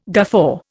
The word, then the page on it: guffaw: Indian